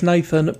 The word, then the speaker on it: nxthxn: So it's Nathan, but two X's instead of the two A's.